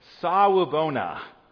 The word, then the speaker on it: sawubona: The word is, and I'll spell it for you, sawubona. Yep, that's it. Sawubona, S-A-W-U-B-O-N-A.